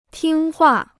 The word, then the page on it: 听话: tīng huà